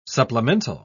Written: supplemental

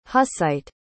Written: hussite

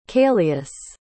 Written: caelius

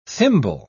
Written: thimble

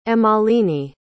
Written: emmaline

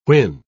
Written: whin

